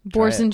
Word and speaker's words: Boursin: Boursin cheese. Everyone makes fun of me because I say it French, but I'm like, that's how you say it.